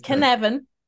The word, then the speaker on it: Cynefin: It's this Cynefin, right? I'm saying that right. Right? – Yep. It's Cynefin. Yeah. – It's a weirdo Welsh word and it doesn't look like what it sounds like.